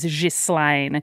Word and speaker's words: Ghislaine: Can I interrupt quickly and say that my grandmother's name is Ghislaine and it's pronounced that way, but, you know, let's go with Ghislaine.